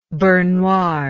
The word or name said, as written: beurre noir